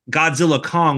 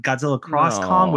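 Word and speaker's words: Godzilla x Kong: We still got friggin' Minus One and Godzilla Kong, which I'm apparently the X is silent. – I heard that this week. – Kong. I heard that as well. I'm like, okay, so it's not Godzilla Cross Kong, which is usually how you're supposed to read it.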